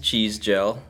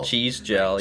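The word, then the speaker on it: Cizdziel: So it's pronounced cheese gel. – Cheese gel. – Cheese gel, yeah. – Cheese gel. – Yeah. – It's like cheese.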